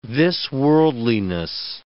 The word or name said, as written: this-worldliness